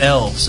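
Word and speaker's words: Elwes: Yes, my giant was Carrie Ewells. – It's Elves. That's how it's pronounced.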